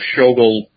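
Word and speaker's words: Schogol: Am I pronouncing it right? – It's close enough. Skogel. – Skogel.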